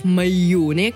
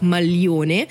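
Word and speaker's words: maglione: Это вот э, варианты вот этого произношения, хотя именно с точки зрения устного итальянского языка это некорректно говорить майоне, корректно именно говорить мальоне, но а, вот люди говорят, и, скажем, такая негласная тенденция как будто бы рождается, что модно говорить вот так.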